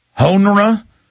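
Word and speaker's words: Honoré: Retired Army General Russell, I believe you pronounce that H-O-N-O-R-E with a French squiggly on the end of it. How do you pronounce that? Honra on CNN.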